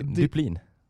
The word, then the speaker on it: Dyplin: Är det Kevin Duplin? – Duplin. – D-Y-P-L-I-N.